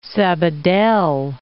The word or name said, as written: Sabadell